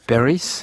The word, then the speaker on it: Paris: Paris, vous prononcez comment ? – Bon, je prononcerais Paris.